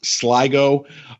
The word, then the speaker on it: Sligo: So there's a town in Ireland, in the northwest part of Ireland, called, I believe it's pronounced Sligo, S-L-I-G-O.